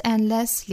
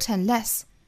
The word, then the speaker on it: unless: Not unless 连读，not unless unless 注意重读在第二个音节，不是 unless 而是 unless，不要读成了 unless，应该是 unless。